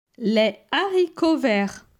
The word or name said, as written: Les haricots verts